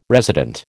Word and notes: resident: American English

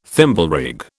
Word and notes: Thimblerig: British English

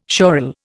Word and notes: schorl: Indian

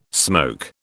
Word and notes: smoke: British English

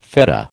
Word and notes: fetta: American English

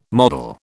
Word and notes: model: British English

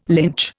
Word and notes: lynch: Indian

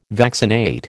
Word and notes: vaccinate: American English

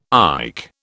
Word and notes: IC: British English

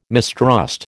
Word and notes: mistrust: American English

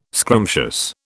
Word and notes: scrumptious: British English

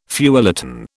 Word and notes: feuilleton: British English

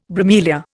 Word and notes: bromelia: Indian

